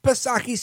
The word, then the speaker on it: Psaki: Sir, that is Jen Circleback Psaki, silent P, of course.